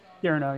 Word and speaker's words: Pierno: How do you say your last name? – Pierno, yeah.